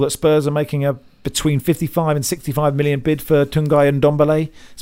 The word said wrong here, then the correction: Tanguy: been Tungay and Ndombele. – Dongi. – Okay. Dongi.